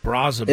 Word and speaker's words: Brazoban: Barbacoa, I kept getting his name wrong. – My goodness. – Barbacoa, huh? That's what you came up with? – What's his actual name? – Brazoban. – It's Waskar Brazoban.